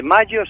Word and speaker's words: Majo: Fíjate, hoy te voy a hablar de Mayo Solís. Su nombre es curioso, porque es mexicano, se escribe Majo, de Mayo Solís, Majo Solís, y de su marido Dan.